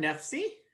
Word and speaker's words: Nefcy: It is, of course, um, created by Daron Nefcy. Nefcy? Is that the pronunciation? right? – Yeah. – Yep.